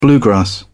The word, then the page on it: bluegrass: British English